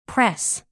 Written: press

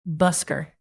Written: busker

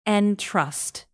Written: entrust

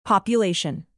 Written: population

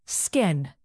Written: skin

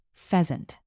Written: pheasant

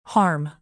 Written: harm

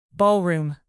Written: ballroom

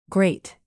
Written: grate

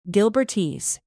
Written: gilbertese

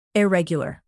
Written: irregular

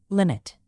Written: limit

